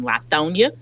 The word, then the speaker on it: Lithonia: They lived out in Lithonia